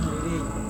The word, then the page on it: harire: Serewen